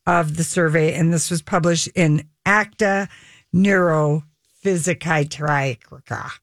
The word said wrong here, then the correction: Neuropsychiatrica: Oh, not that one. – I don't know how to say this word. Let me try it again. – That's your Danish. – Neuropsychiatrica. – Oh. – ACTA Neuropsychiatrica. Wow, that's a mouthful.